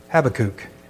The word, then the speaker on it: Habakkuk: And I kind of like how Merritt Pryor, he would pronounce it differently. He pronounced it, or at least what I understood, the way I heard it was Habakkuk.